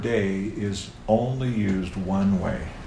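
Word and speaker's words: day (δεῖ): It's just three Greek letters. Delta, Epsilon, Iota. We pronounce it day. It comes in several different forms, but that's the primary form, day.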